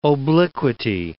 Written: obliquity

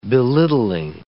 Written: belittling

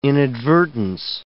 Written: inadvertence

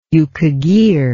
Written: yukagir

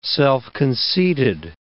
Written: self-conceited

